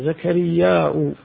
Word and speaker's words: زكرياء: أي نقول فيها يجوز الوجه، الأصل فيها همزة زكرياء، لكن العرب تستثقل الهمزة فأحيانا يخففونها مثل ذئب وذيب وبئر وبير وما شابه ذلك، فإنه إذا كان فيه همزة في الكلمة فإنهم أحيانا ينطقونها وأحيانا يتركونها تخفيفا. فما قال زكرياء هذا صحيح، فيها قراءة بالهمزة وقراءة بدون الهمزة كذلك.